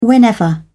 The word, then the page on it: whenever: British English